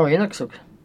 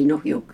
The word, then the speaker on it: Inuksuk: Oh, Inuksuk. – Huh? – Inuksuk. – How do you say? – Inuksuk. – Inuksuk. What did I say? Inuhyuk. Yeah. Inuksuk.